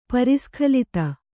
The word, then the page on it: ಪರಿಸ್ಖಲಿತ: pari skhalita